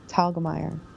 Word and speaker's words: Telgemeier: I will be discussing Drama, the amazing graphic novel by Raina Telgemeier. I can never pronounce it right. – Telgemeier.